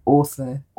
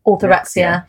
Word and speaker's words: orthorexia: I'm going to say it wrong. Author- Orthorexia.